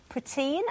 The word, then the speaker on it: poutine: Now, this is poutine.